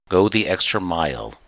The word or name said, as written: go the extra mile